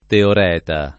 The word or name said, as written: teoreta